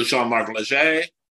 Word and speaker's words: Jean-Marc Léger: And then we know Jean-Marc Léger. Did I say that right? – You did.